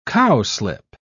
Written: cowslip

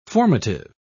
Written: formative

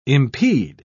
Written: impede

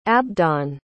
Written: abdon

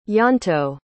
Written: ianto

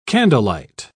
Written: candlelight

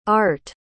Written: Aart

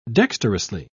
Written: dexterously

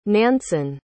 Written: nansen

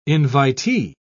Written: invitee

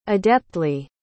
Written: adeptly

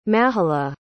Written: mahala

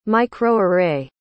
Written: microarray

